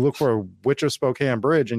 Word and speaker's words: Spokane: And yeah, it's pronounced Spokane, you idiot.